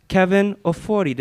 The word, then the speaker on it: Kevin Ofori: I'm extremely humbled and excited to introduce to you Kevin Ofori.